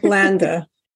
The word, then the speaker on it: Landa: Landa.